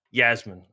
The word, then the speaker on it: Yasmine: Yasmine. – All right. – I mean, I'm German, so Yasmine is how we would pronounce it, but that's very uncommon here in Australia.